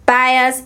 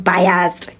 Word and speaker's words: bias: For a very long time, thank you Jesus that I opened my my eyes and I found out that bias is not biased. Ghanaians say biased.